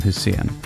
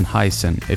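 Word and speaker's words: Hysén: In this week's episode, I had the opportunity to sit down with Anton Hussein, or Anton Heisen, if you prefer the English pronunciation.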